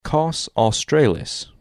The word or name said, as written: Kaus Australis